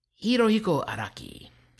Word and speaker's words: Hirohiko Araki: Hirohiko Araki.